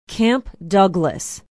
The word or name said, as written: camp douglas